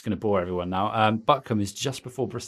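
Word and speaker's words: Butcombe: I know Buckham very well. – You don't pronounce it as coom when you're in the West Country.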